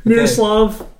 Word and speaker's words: Miroslav: That is Mirslava Satan. – Miroslav.